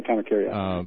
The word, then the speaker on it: Kamakiriad: Kamakiriad, is that how it's pronounced? – Yeah. – Okay, Kamakiriad.